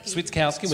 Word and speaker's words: Switkowski: When Ziggy Stokowski, is that how you say his name? – Yeah. – Switkowski.